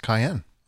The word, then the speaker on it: Kion: I believe it's pronounced cayenne. – Oh, like the pepper? – Like the pepper.